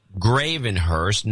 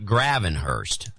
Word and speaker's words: Gravenhurst: And tell John I do know it's pronounced Gravenhurst, not Gravenhurst.